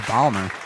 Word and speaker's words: Baltimore: Baltimore. Oh, you're saying it wrong. Baltimore. – Yeah, you're both saying it wrong. It's Baltimore.